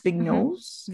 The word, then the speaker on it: Vignoles: I think it was called the, I'm going to butcher this name, but it was like a Vignoles, V-I-G-N-O-L-E-S, Vignoles.